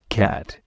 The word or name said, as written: cat